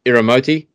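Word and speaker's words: Iriomote: I'm going to pronounce this correct. Iromoti Island, Okinawa. Iromoti.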